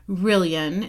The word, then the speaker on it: Rillian: One of the names we are considering is the name Rylan, or Rillian. no, Rillian, pronounced like billion or million.